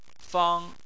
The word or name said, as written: fang1